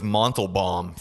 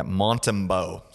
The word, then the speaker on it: Montembeault: I thought his name was Montelbaum for the longest time, and then I read closer. Yeah, Montembeault.